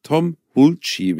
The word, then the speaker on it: Hulce: Tom Hulce wird der ausgesprochen, oder Hulce. H-U-L-C-E.